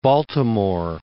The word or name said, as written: Baltimore